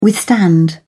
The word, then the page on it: withstand: British English